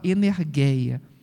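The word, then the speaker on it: energeia (ἐνέργεια): A energeia do grego, o G deles não pronuncia como o nosso G de, de Geraldo, não pronuncia como G. Então, em vez de energia a gente fala energeia. Energia.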